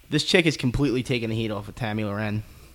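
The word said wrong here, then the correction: Tomi Lahren: That's not how you say her name. – Tommy Loren? – Tommy Lauren.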